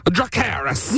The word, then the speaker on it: Dracarys: Dracarys. Is that how you say it for Game of Thrones? – Dracarys. – Yes, that's it.